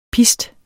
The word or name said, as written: PISTENE